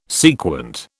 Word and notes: sequent: British English

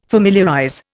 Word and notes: familiarize: Indian

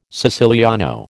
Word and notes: siciliano: American English

